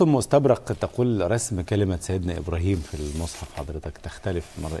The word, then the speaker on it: إبراهيم: النطق في جميع الحالات إبراهيم.